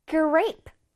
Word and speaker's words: grape: 자, grape는 어떻게 발음을 해야 되냐면, gr, gr를 아주 강하게, 강하게 한번 발음해 줘볼게. gr, gr. 약간, gr 하는 것 같지 않아, 얘들아? 다시, gr. grr 그다음에 ape는 길게 ape